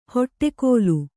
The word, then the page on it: ಹೊಟ್ಟೆಕೋಲು: hoṭṭe kōlu